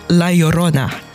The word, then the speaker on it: La Llorona: Ik heb het opgezocht en het is eigenlijk meer zo La Llorona. – Ja, ja, zo het begint met twee L'en, dus dat is de J-klank.